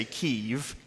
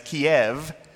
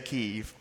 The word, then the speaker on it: Kyiv: By the way, I'm going to say Kyiv, because if you're from Ukraine, you say Kyiv. I grew up hearing it as Kiev. You say Kiev if you're from Russia. So I'm going to choose the Ukrainian pronunciation and say Kyiv.